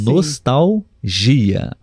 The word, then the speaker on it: nostalgia: É, ele colocou: nostalgia é a única palavra nesta lista onde o L não se encontra na sílaba tônica, viu?